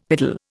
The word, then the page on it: piddle: Indian